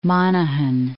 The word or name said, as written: Monaghan